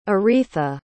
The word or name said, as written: Eritha